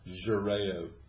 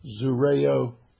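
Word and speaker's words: xurao: And so he had to shave also, which is called X-U-R-A-O. I don't know how you pronounce the X. Is that Z? – What was Zureo? – Huh? – Zureo. – Zureo. Yeah, that's what I thought. Because generally, with an X in something, if it's dealing with mankind or dealing with an affliction or whatever, it's generally a Z that's pronounced with that.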